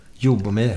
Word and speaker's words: Ljubomir: Säger man Ljubomir eller vad säger man? Exakt hur man säger. – Ljubomir. Jobomir. Ja, Jobomir.